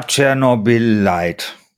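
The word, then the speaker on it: Chernobylite: Ja, Chernobyl Light. – Das spricht man so aus.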